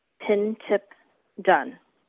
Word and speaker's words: Pintip Dunn: Hello, my name is Pintip Dunn. There's nothing fancy about the pronunciation. It's said exactly how it's spelled. Pintip Dunn.